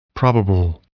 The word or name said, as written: probable